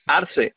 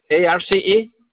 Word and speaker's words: Arce: My last name is Arce. – Can you spell that? Can you spell that for me? – Excuse me? – Can you spell that for me? – Francisco, like San Francisco City. Arce, ARCE.